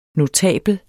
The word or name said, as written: notabel